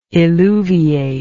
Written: eluviate

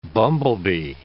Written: bumblebee

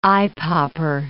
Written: eyepopper

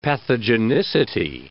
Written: pathogenicity

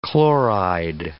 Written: chloride

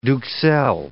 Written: duxelles

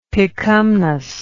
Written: picumnus

